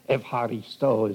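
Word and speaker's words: evharisto (εὐχαριστῶ): The very word evharisto is the way the Greeks would pronounce it.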